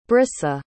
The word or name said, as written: brissa